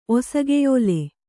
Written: ಒಸಗೆಯೋಲೆ

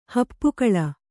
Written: ಹೆಪ್ಪುಗೊಳ್

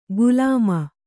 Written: ಗುಲಾಮ

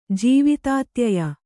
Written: ಜೀವಿತಾತ್ಯಯ

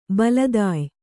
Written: ಬಲತಾಯಿ

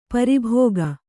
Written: ಪ್ರಭಾಗ

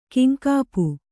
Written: ಕಿಂಕಾಪು

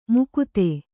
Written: ಮುಕುತೆ

